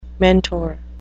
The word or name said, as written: mentor